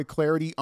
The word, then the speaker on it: Clardy: At Troy Clarity on Twitter, the last name is spelled C-L-A-R-D-Y.